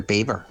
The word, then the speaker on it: Belvoir: When I was young, I lived in a place near Beaver. And they had a... – Now, can I stop Mike there, right? Why is Beaver called Beaver? Why is it not pronounced Belvoir? – I don't know.